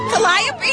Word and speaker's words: Calliope: Smelling like Calliope. – Calliope? Is that how you say it? – Yeah.